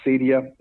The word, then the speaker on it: acedia: like a lack of spiritual hunger and acedia. I think I pronounced it right, that Greek term. – Yep.